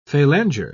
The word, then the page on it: phalanger: British English